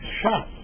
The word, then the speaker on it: Shas: That initial, Shin for Shisha, Samach for Sidarim, those two letters came to be used together as a contraction, pronounced Shas, and Shas is the Talmud.